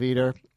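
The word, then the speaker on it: Eder: And Steve Eater, rhymes with Jeter, investigative sports reporter for The New York Times, has been looking into it.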